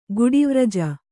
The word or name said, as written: ಗುಡಿವ್ರಜ